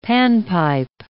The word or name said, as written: panpipe